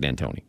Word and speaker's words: D'Antoni: It's Mike D'Antoni. – D'Antoni. – And I'll let you hear, before you answer this question, I'll let you hear exactly how he put it. This is Mike D'Antoni about where the pressure lies in the Western Conference Finals.